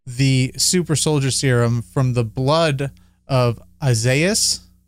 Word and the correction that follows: Isaiah: Did I say it right? – Isaiah. – Isaiah. – I called it.